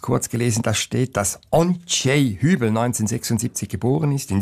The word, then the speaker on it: Ondřej: Aber das muss man, glaube ich, tschechisch aussprechen. Simon, hilf mir kurz. – Ondřej Hübel, würde ich sagen, aber ich bin auch kein Experte. – Ondřej, genau.